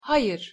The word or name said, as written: hayir